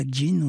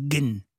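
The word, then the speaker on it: gen (جن): C'est-à-dire que on utilise le mot, enfin, en arabe égyptien, on ne dit pas djinn, on dit gen. – Ouais. – Parce qu'on ne prononce pas le dje, on dit ge à la place.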